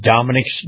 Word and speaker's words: Domenick's: That's Dominic's Blinds and Decor of Sarasota. Their name is spelled D-O-M-E-N-I-C-K-S, so when you Google them, Google Dominic's Blinds and Decor, and it'll come up Dominic's Blinds and Decor of Sarasota.